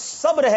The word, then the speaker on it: صبر: صبر ہیں سوچ رہے ہو, لوگ تو یہ کہتے ہیں صبر سے کام لو, وہ غلط کہتے ہیں. جی جناب جواب بالکل درست ہے, اس کا صحیح تلفظ صبر ہے.